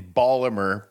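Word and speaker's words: Baltimore: He was born in Ballamer. – But town. – That's how you say it. That's, my family's from Ballamer.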